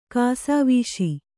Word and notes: ಕಾಸಾವೀಸಿ: kāsāvīsi; Kannada

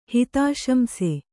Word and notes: ಹಿತಾಶಂಸೆ: Kannada

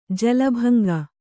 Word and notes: ಜಾಲಬಂಕೆ: jalabaŋke

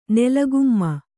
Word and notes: ನೆಲಗುಮ್ಮ: nela gumma

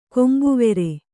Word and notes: ಕಂಬಿವಾರು: Kannada

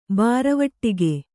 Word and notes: ಬೂರವಟ್ಟಗೆ: Kannada

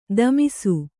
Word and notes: ದಮಿಸು: damisu

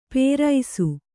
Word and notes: ಪೇರೈಸು: kannada

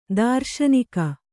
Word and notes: ತೃಷ್ಣಕ: třṣṇaka

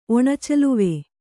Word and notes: ಒಣಚಲುವೆ: Kannada

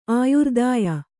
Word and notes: ಆಯುರ್ದಾಯ: āyurdāya

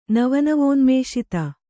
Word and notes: ನವನವೋನ್ಮೇಷಿತ: nava navōnmēṣita

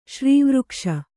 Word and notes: ಶ್ರೀವೃಕ್ಷ: śrīvřkṣa; kannada

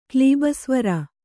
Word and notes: ಕ್ಲೀಬಸ್ವರ: Kannada

